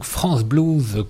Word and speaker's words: France Blues: C'est donc France Blues, comme ça se prononce, et c'est tout attaché, .com.